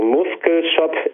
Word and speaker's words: Muskel Shop: Du hast es Muscle Shop EU ausgesprochen. Es wird mit K geschrieben, also Muskel Shop EU.